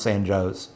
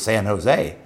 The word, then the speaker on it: San Jose: It's spelled like San Jose, and it's pronounced San Joe's. And we would get these anchors who would come in from other markets, and they would assume that it was San Jose. And one of the producers took to writing J-O-E apostrophe S to get these anchors to say San Joe's.